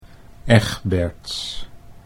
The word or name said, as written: Egberts